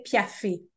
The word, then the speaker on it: piaffe: So in French, we say Piaffe, you know, because there's that little accent on the E. But I know in English you say Piaffe, so we'll say Piaffe.